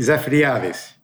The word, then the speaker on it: Zafiriadis: Zafiriadis. – Zafiriadis. So you, you pronounce the A as well.